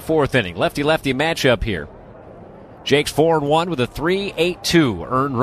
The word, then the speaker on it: Jacques: Spelled Jacques, but they tell us in the pronunciation guide for Indianapolis, it is pronounced Jakes. And so I will believe them. J-A-C-Q-U-E-S.